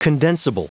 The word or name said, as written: condensible